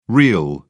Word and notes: reel: British English

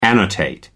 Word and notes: annotate: American English

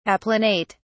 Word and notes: applanate: British English

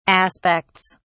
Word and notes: aspects: British English

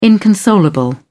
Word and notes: inconsolable: British English

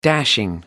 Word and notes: dashing: American English

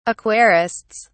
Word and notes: aquarists: British English

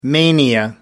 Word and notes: mania: American English

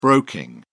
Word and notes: broking: British English

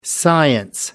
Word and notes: science: American English

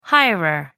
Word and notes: hirer: American English

